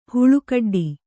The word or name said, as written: ಹಲ್ಲುಕಡ್ಡಿ